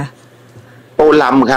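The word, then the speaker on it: Tô Lâm: โตลำ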